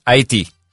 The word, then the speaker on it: Haiti: Allora, la pronuncia corretta è Haiti? – Haiti. – Haiti, vedi, ero andato abbastanza vicino comunque.